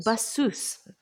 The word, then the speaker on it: Bassous: Is it Bassous or Bassous? Do we pronounce the S? – Bassous. Yes. – Bassous.